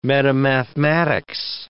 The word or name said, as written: metamathematics